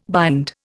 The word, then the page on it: bind: Indian